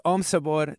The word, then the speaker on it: Om Sabor: That was Om Sabor. That's like O-M and then like Sabor, like flavor in Spanish, S-A-B-O-R.